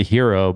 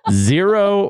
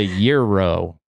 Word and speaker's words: gyro: I always pronounced it the hero, but apparently it's either the zero or the euro.